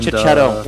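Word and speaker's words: Checchetto: Marcus and, Cicchetto.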